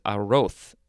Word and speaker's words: arroz: The pronunciation guide actually says arroz, like a TH sound, arroz.